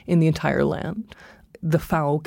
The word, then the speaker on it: FAO: So the Food and Agriculture Organization, the FAO, I pronounce it the way that they pronounce it in Italy because the headquarters are in Rome. FAO rhymes with cow.